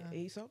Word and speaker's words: Aesop: So, and I think we came to an event at Aesop, how do you pronounce it? – Aesop.